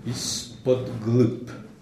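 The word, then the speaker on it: ispodgrup (Из-под глыб): Mais la traduction française est forcément maladroite parce que en russe c'est trois syllabes, ispodgrup, et qu'en français on n'arrive pas à rendre le côté lourd, massif, trois syllabes de ce titre.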